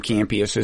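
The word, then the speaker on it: Kampeas: He pronounced his name Campeas, as it turns out.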